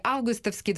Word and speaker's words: августовский: Это августовские погоды, вот такое ударение. Оно, а путч все-таки августовский, 25 лет тому назад проходили эти события.